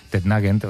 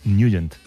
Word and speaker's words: Nugent: Hombre, siempre lo hemos llamado en el barrio Tednagent o Tednagent. – Claro, ¿verdad? Yo era de Tednagent. – Pero la pronunciación correcta es Nugent. – Te fuiste a Washington y descubriste que era Nugent.